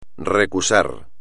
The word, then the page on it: recusar: espagnol